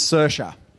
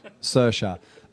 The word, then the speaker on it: Saoirse: It's Saoirse. Yeah, and don't worry, I had to be told myself. The first thing I asked when I spoke to Joe, how do I say her name? Saoirse.